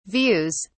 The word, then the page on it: views: British English